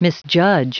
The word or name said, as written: misjudge